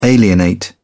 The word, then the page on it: alienate: British English